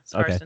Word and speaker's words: Skarsten: Is that how you pronounce that? – I believe so, yeah. Skarsten.